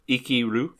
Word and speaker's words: Ikiru: So this week, we are talking about Ikiru. Did I say that right? – Yeah, it's a pretty easy one.